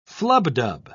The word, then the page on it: flubdub: British English